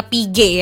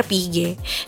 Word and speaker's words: Piguet: Jadi intinya Piguet ya dibacanya, Audemars Piguet.